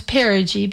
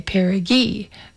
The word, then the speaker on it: perigee: Now, I know how to pronounce perigee because I rehearsed it. It's like putting on a new pair of jeans, because otherwise I might be tempted to say perigee. But no, it's just like putting on a pair of jeans, perigee.